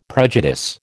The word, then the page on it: prejudice: American English